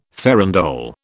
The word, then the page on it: farandole: British English